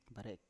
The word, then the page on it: barek: Biak